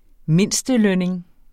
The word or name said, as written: mindstelønning